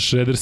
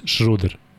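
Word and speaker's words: Schröder: Ozrene, koji kaže Šreder se izgovara, on je izašao i rekao ja sam Denis Šruder.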